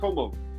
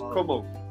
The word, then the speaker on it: Comeaux: My last name is pronounced Comeaux. E-A-U-X, yeah, E-A-U-X in French is like oak, so it's just Comeaux.